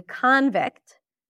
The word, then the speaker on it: convict: When it's a noun, she's a convict. Convict, then this is going to be the as in father. Primary stress. Convict.